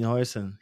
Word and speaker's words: Huijsen: You have Dean, is it Huijsen? How do I pronounce the last name? – Dean Huijsen. He played well today, though.